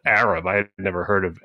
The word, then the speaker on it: Arab: Okay, the town is pronounced Arab.